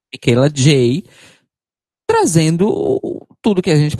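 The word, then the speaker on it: Michaela Jaé: Como é que é a pronúncia? É J? É, é, segundo ela própria, é Micaela J.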